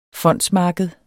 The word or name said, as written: fondsmarked